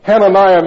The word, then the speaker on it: Hananiah: Hananiah means Yah, right at the end of A-H is pronounced Yah, you know, in our terms.